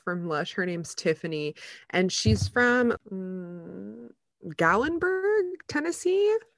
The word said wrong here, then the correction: Gatlinburg: I might be saying that wrong. It's like Gatlinburg.